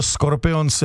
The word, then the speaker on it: Scorpions: Protože nikdo neříká Scorpions, všichni říkají Scorpions. – To je vlastně pravda, vždycky jsme říkali škorpíci nebo škorpioni, ale jsou to Scorpions jako německy vysloveno.